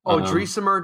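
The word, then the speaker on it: Odrisamer: Pronunciation was Odrasmir Despaigne. – Odrisimer Despaigne.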